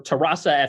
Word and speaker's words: Terrassa: You're playing right now for Terrassa FC. By the way, do I have the pronunciation on that right? – Yeah, that's pretty good.